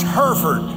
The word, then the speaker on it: Hereford: The English call it Hereford, but they don't know how to pronounce it just because that name comes from England. It's Hereford, up in the panhandle of Texas.